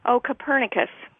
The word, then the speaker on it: Copernicus: Copernicus, yes.